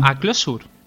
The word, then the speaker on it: Clojure: Se escribe, se clojure, ¿no? Pero no se pronuncia así. – Sería más ah, mm. a closure.